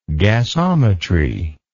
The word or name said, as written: gasometry